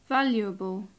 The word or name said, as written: valuable